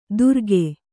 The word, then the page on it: ದುರಗೆ: Kannada